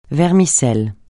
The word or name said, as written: vermicelle